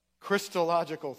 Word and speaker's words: Christological: This is probably one of the most, I hope I say this right, Christological passages in the Bible. Christological, thank you.